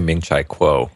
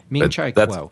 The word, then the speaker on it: Ming-Chi Kuo: Okay, so there's a report that came out from KGI Securities analyst Ming-Chi Kuo or Cao. – I would say Ming-Chi Kuo. – Ming-Chi Kuo. – That's only because I think that's how Ken Ray pronounces it, and I use him as my barometer for all things pronunciation when it comes to names. – Okay, well, there you go. We'll go to the Ken Ray School of Pronunciation, and there you go.